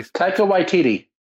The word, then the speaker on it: Taika Waititi: All right, it's like Takiti. I can never say his name. Anybody? – Taika Waititi.